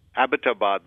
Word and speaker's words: Abbottabad: And incidentally, Kathleen, this is the first time I'm mentioning this, no disrespect here, but the pronunciation is Abbottabad because the word comes from Abbott, which is an English name.